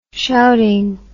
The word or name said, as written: shouting